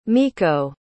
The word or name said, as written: miko